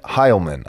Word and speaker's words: Heilman: And in this particular episode, I'm privileged to have as a special guest, Shane Heilman.